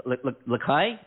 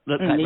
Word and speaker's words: La'ki: Look at what Lakai, is that, am I saying that right? – Yes, that's right. – Lakai, right.